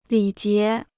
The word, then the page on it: 礼节: lǐ jié